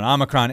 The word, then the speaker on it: Omicron: That will come to America if they are going to clamp down on this new, was it Omicron? Did I say that right? I don't. – Omicron.